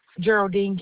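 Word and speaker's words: Jeraldine: Geraldine Canty. – Geraldine. G-E-R-A-L-D-I-N-E. – No. J. – J-E-R-A. – Uh-huh. – L-D-I-N-E.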